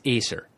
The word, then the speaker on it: Aesir: I put the accent on the first syllable. I think it's Aesir.